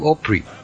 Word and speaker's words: Opry: Ahí en Nashville existe lo que llaman el Grand Ole Opry. Que Opry es una ópera, mal pronunciada, ¿no? – Sí.